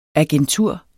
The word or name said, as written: agentur